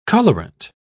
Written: colorant

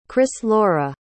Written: chrislaure

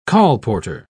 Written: colporteur

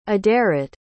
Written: aderet

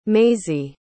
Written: mazie

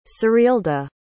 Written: serilda

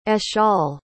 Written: eshaal

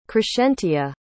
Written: crescentia

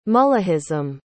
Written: mullahism

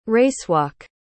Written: racewalk

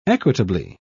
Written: equitably

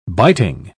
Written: biting

